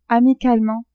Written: amicalement

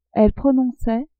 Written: elles prononçaient

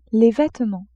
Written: vêtements

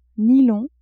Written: nylon

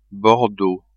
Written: bordeaux

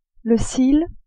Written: cil

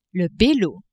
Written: Belau

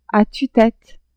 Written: a tue-tete

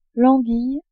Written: anguille

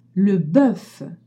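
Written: bœuf

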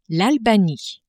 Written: Albanie

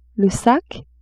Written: sac

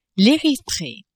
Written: Erythree

